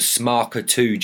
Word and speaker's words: SMARCA2: So it is called the SMARCA2 gene, which is located on chromosome 9. – Wow. Okay. SMARCA2.